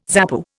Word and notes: ZAPU: Indian

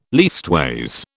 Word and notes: leastways: British English

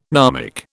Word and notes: gnomic: British English

